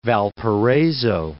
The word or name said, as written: Valparaiso